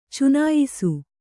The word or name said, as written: ಚುನಾಯಿಸು